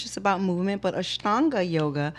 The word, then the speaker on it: Ashtanga: Enshante? – Ashtanga? – Ashtanga? – Ashtanga.